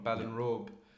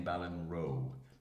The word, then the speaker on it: Ballinrobe: Ballinrobe is the small country town. – Well done on not saying Ballinrobe, which is what people usually do. – Really? You mispronounce it? Forget the B.